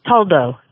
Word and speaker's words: Toldo: Now, this is the cat's name is it pronounced Toldo? – Toldo, yes. – T-O-L-D-O.